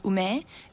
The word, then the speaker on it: ume: But if you look at the name, in Chinese they call it mei, in Japanese it's ume, and in Korean it's meshi.